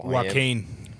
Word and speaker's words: Joaquin: Now, I don't know how to pronounce this bloke's name, so I apologise, but Joaquim? J-O-A-Q-U-I-M. – Joaquin. – What is it? – Joaquin, sorry, brother.